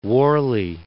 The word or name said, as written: Warley